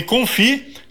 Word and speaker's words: confit: O nome confit se escreve confite com T mudo,